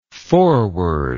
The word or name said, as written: forwardable